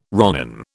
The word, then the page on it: ronin: British English